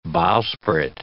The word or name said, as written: bowsprit